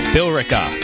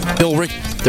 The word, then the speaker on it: Billerica: It's Billerica? Bill? What? How do you pronounce that? Billerica. There's an E in there. – Billerica. Billerica, maybe? – Billerica. – Oh, that makes sense. Just leave out the E.